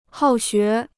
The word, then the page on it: 好学: hào xué